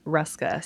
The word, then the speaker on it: Hruska: Yes, Lauren Hruska, silent H.